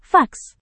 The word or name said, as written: fax